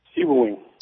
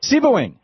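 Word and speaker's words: Sebewaing: Tim, in Sebowing, how do you say that in Michigan, the town? – Sebowing. – Sebowing. Okay, there you go.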